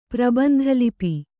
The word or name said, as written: ಪ್ರಬಂಧಲಿಪಿ